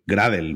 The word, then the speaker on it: Gradle: Le vamos a llamar Gradle, pero para los que le suene la pronunciación inglesa un poco a chino, vamos a decir que esto es Gradle, ¿vale?